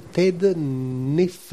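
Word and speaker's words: Naifeh: Courtney Crumrin, le tome 5, Courtney Crumrin et l'apprenti sorcière de Ted Neff. Je ne sais pas comment du tout on peut prononcer ça. – Neff. – Voilà, ça me fait le Neff.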